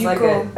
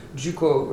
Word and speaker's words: Ducos: And in French, it's Ducos. So it's almost Ducos. Like a J and a D attached together, even though it's just D-U-C-O-S, and the S is silent. Juco, right?